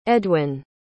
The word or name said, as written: edwin